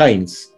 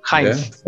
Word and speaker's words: Heinz: Bueno, en verdad es Heinz. Heinz. Heinz, sí. Eh, nombre alemán, bueno, que soy descendiente. Heinz, eh, sí. Estoy, bueno, ya de todo el tiempo que vengo eh, viviendo en Chile, ya estoy acostumbrado a que me digan eh, Heinz, Hans.